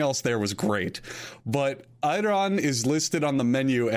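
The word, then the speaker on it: Ayran: I don't know how you pronounce it. Is it Iran? – It's Iran.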